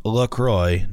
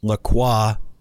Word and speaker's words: LaCroix: L-A capital C-R-O-I-X is pronounced LaCroix, not LaCroix or LaCroix, like the French might say it. – Okay. – The company even explains on its website that the name is pronounced LaCroix and helpfully mentions that it rhymes with enjoy.